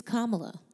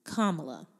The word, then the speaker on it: Kamala: It's Kamala. If you think of a comma and then you add la, Kamala.